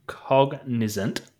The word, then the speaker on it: Cognizant: I'm going to butcher this. The Cognizant. Is that it? – Yeah, that's it.